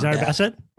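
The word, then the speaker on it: Zered Bassett: It's not Zara Bassett. Bassett, isn't it? – Yeah. Zara Bassett.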